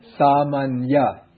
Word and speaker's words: samanya: So when you have ny joined together, that changes into these two, yeah. – Samanya, nya, just like the N in onion, samanya. But you can't really pronounce both of them. So the first one really becomes just like the ordinary N, and then the second one is like the NY.